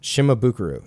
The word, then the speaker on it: Shimabukuro: Shimabukuro.